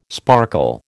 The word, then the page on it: sparkle: American English